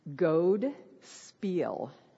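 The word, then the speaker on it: godspel: And in Old English, it is pronounced goad spiel. So what this means in Old English, goad is good and spiel is a story.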